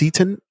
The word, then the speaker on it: seitan: No, it's called, I think it's pronounced Seitan, S-E-I-T-A-N.